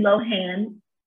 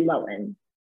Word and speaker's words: Lohan: And she set up a firestorm by merely pronouncing her name with the emphasis on a long O and not the H. So wow. – The whole time I've been saying Lindsay Lohan, but right now she's sending it straight with Lindsay Lohan.